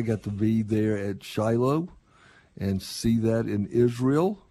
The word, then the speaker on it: Shiloh: Yeah, if you do go, again, it's pronounced Shiloh.